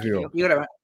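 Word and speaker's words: Kiwior: That's right. Kivior. – You said it right the first time.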